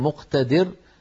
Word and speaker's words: مقتدر: بدل أن تقول أن تكون مقتدر بالدال من القدرة تصير بالذال، ونسأل الله العافية، معنى لا نستطيع التلفظ به قد يصل بصاحبه إلى ما يصل.